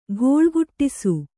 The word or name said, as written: ಗೋಳುಗುಟ್ಟಿಸು